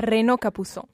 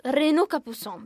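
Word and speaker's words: Renaud Capuçon: Renaud Capusson. – Renaud Capusson, benissimo.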